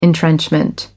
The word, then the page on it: entrenchment: American English